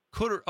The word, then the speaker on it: Couderay: Did you say Corduroy? – Am I saying that wrong? It's spelled phonetically. It looks like Corduroy. Corduroy? – Ends with an A. – Oh, no. Did I get Couderay. – Oh, is that how you say it?